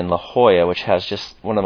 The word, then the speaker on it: La Jolla: So it's spelled J O-L-L-A, but it's pronounced La Jolla.